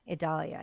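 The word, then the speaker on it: Idalia: try to say it like Vidalia, but it's not that. – Yeah. – It's Adalia. Adalia, I think.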